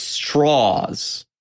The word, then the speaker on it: Strauss: is the part of the machinations of Strauss, or as he keeps saying to everybody in the movie, it's straws. – It's straws. – That's straws. That's how he pronounces it.